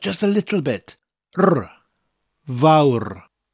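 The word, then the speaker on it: fawr: Now, hoyl vaur, the vaur bit is pronounced like the English word vow, but with that little bit of a rolling R at the end of it.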